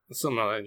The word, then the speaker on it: Silmarillion: You've read this Silmarillion? Is that how it's pronounced? – Silmarillion, yeah.